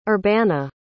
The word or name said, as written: urbana